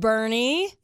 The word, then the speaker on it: Boerne: Somebody else says B-O-E-R-N-E, which looks like Bourne, Texas. – Bernie. – Is pronounced Bernie.